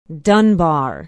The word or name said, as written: dunbar